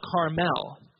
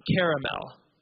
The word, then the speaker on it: Carmel: I am the prophet of God, and we're going to meet on this mountain called Mount Carmel, or Carmel, not caramel, or Carmel. If you say, I'll have a caramel frappuccino, you're wrong. The word is pronounced caramel, because there's a second A in the word. And that second A didn't forget about you, so don't forget about it. But this is C-A-R-M-E-L.